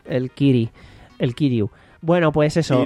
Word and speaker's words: Keighley: Es que se escribe raro porque parece que se tenga que decir Keighley, pero en toda la presentación dicen Keighley.